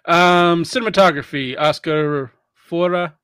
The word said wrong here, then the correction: Faura: Edited. – Oscar Faura. – Faura, thank you.